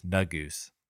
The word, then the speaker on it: Negus: Kendrick's piece is based on the Ethiopian word for king, which he pronounces Negus, spelled N-E-G-U-S. From the accounts I've read, the Ethiopian pronunciation is Nugus.